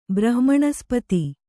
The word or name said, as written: ಬ್ರಹ್ಮಣಸ್ಪತಿ